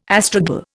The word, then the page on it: astragal: Indian